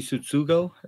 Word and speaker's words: Tsutsugo: Is it Tsutsugo? How do you say his name? – Yeah, Yoshi Tsutsugo.